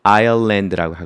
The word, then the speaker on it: island: 그러니까 바닷물 속에 있는 섬, 그걸 아일랜드라고 하거든요. 써볼까요? I-S-L-A-N-D. 아일랜드, S는 묵음이 되겠죠.